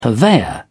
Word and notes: purveyor: British English

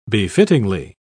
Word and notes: befittingly: British English

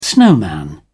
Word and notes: snowman: British English

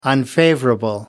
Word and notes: unfavorable: British English

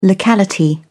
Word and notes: locality: British English

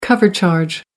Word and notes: cover charge: American English

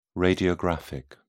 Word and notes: radiographic: British English

